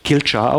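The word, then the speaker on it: Coillte: Da steht irgendwie was ganz anderes, aber im Gälischen spricht man das tatsächlich Kilchar aus.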